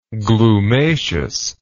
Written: glumaceous

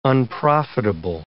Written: unprofitable

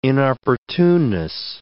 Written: inopportuneness

